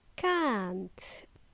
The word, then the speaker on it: can't: The word can't, which is a contraction of cannot, okay? Can't. It's a long sound. Can't. Or a long sound.